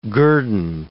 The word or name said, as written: guerdon